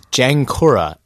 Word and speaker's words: Jankura: Actually, the original pronunciation is Jankura, and what it is actually the family name of the owner of the space, Mike Stewart.